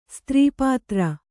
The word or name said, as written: ಸ್ತ್ರೀಪಾತ್ರ